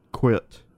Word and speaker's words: Jason Quitt: Quit.